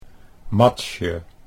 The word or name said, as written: Matsje